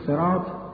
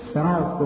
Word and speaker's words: صراط: میتوان اول اون رو هم به سین تلفظ کرد، هم به ز تلفظ کرد، هم به صاد تلفظ کرد. همان گونه که شما عزیزان در کلمه صراط